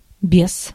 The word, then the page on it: sans: /sɑ̃(z)/; /sɑ̃/